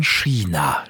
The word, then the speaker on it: China: Wenn es um Frau Easton geht, dann sagt man China.